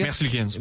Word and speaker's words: Merzlikins: Ils reçoivent aussi le gardien Elvis Merzlikins, c'est-tu comme ça qu'on prononce, les gars? – Merzlikins. – Ouais. Merzlikins.